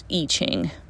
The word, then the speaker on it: I Ching: And I Ching is spelled I, and then a separate word, Ching, C-H-I-N-G, but it is pronounced I Ching.